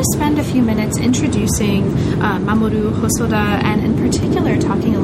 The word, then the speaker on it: Mamoru Hosoda: And this is the figure of Mamoru Hosoda. Am I pronouncing that sort of not entirely wrong? – That's just right. Okay. Mamoru Hosoda. Just perfect.